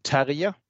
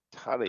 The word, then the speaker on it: Terje: Uh, my name is Terje Simonsen. That's a Norwegian name, and I have yet to meet an Englishman or a guy from the US that will pronounce it correctly. So it's Terje Simonsen. Tarrier.